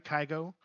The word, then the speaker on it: Kygo: Am I saying it right, Kygo? I couldn't be older. Right? – Sure. – I couldn't be more of a boomer right now. K-Y-G-O? Is that how you say it? Kygo? – I think you're right.